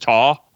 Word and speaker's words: Ptah: I'd want to pronounce the P in it, but it's probably just Ta. – Spell it again. – PTAH. Ptah. Is it? – Yeah, it'd just be like Ta. – Not Ptah. – Like Ta. Like you kind of say the P and the T together.